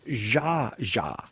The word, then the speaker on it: Zsa Zsa: It's Zsa Zsa. You said Zsa Zsa. Well, I don't know what they this is a major. – It's Z-S-A.